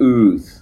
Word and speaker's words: Ooth: So, okay, the album is called Who Cares What You Think, and the band is called Ooth, and it's spelled O-O-O-T-H. So, that's it. – And is this correct pronunciation of that, Ooth? – Yeah, it's called Ooth, yeah, that's Ooth.